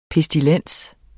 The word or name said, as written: PESTILENSERS